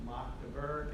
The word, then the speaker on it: Magdeburg: And so we're going to dive into the Magdeburg Confession, which is a small city in Germany, and it is pronounced Magdeburg. And so that should be really easy for us to remember. We're all in the Berg, right? Magdeburg, okay? Mock, you know, mocking. Magdeburg is how you pronounce this.